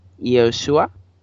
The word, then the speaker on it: Yeshua: Entonces, la forma correcta de pronunciarlo es Yeshua. Digo, Yeshua.